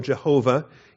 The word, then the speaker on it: Jehovah: There are no vowels. As such, it's a little bit awkward to pronounce. And so Yahweh is a approximation of the pronunciation. Also, Jehovah is an approximation of the pronunciation.